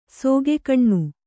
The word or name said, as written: ಸಿಗ್ಗನೆ